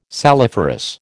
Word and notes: saliferous: American English

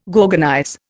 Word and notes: gorgonize: Indian

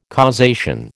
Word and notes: causation: American English

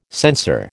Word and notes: censer: American English

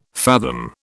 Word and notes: fathom: British English

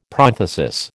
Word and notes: prothesis: American English

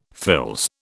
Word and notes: fils: British English

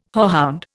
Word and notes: hoarhound: Indian

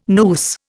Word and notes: noose: Indian

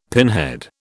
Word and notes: pinhead: British English

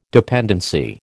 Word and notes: dependency: American English

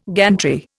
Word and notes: gantry: Indian

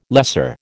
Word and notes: lesser: American English

